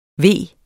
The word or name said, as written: ve